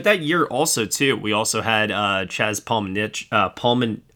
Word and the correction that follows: Palminteri: Oh, my God, I can never say it right. – Palminteri? Yeah. – Yeah, thank you.